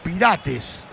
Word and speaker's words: Pirates: Tal cual, sí, Apolis que juega en el Orlando Pirates de los Estados Unidos. ¿Qué es la pronunciación? – Pirates, ahí está, perfecto.